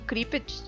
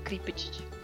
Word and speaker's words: Cryptid: Eu gostei porque esse, o Creeped, não sei se falei certo, Creeped, não sei. – Acho que é isso aí mesmo.